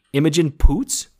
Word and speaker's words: Imogen Poots: And he wants to introduce him to Laura, played by, I might say this name totally wrong, Imogen Poots. – That's perfect. That's exactly how you say it.